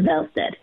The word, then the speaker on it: Wallstedt: And he's like, it is Wallstedt.